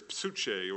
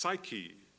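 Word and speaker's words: psuche (ψυχή): The word there is psuche, or sometimes it's pronounced psyche, as in psychology.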